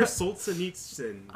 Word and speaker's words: Solzhenitsyn: I want to say Solzhenitsyn. – Tune in next week to hear us butcher more Russian names. No, Alexander Solzhenitsyn.